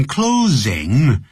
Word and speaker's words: closing: Elaborate 是怎么拼的呢 E-L-A-B-O-R-A-T-E. – Please elaborate on what's happening. 有关发生的事情你可不可以详细说明啊，也可以用这样的用法。还有 in closing, close, 啊就是关闭的意思，所以那个 S 要念成 Z, in closing, do the in conclusion.